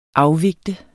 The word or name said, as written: afvigte